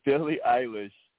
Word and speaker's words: Billie Eilish: Billie Eilish.